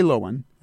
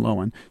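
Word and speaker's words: Loewen: Lowen, and that's spelled J-L-O-E-W-E-N. That's my name, Jim Lowen, J. Lowen, @ zoo, like the animal, Z-O-O, .UVM, because I used to be at the University of Vermont, .edu.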